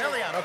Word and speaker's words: Melean: Did I say it right? Yes. Melean. Okay, wanted to make sure.